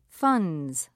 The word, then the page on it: funds: British English